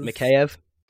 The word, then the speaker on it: Mikheyev: Mikheyev? – Yeah, there we go.